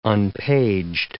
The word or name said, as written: unpaged